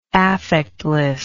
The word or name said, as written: affectlessness